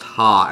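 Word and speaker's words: ha (ห้า): The number five in Thai is pronounced ha, and is used to say lol in text message or online.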